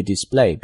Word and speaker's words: display: 在这里这个 display 表示的意思就是展示，注意一下它的读音，不要读成 display，不是一个 p sound，它不是一个呼出音，而是一个没有呼出的这个声音，所以读的呢有点像我们所谓的叫做浊化的这个 b sound。